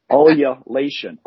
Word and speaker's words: ululation: It's U-L-U-L-A-T-I-O-N. It has several different pronunciations, which gives me a better chance of saying it correctly. Ululation. Ululation is one of them.